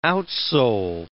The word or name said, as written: outsole